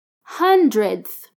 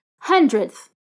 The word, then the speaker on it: hundredth: When you pronounce the th, the d becomes really subtle. Hundredth. Repeat, hundredth.